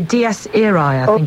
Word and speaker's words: Dies Irae: We're going to listen to the Dies Irae, is how you pronounce it.